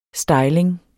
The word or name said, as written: styling